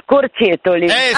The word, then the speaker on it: Scorcetoli: Ciao, dimmi prima di tutto come si pronuncia. – Allora, si pronuncia Scorcetoli.